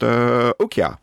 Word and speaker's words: Okja: Netflix has an upcoming thriller from South Korean director Bong Joon-ho, which I'm going to say is pronounced Okja, O-K-J-A.